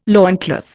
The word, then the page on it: loincloth: Indian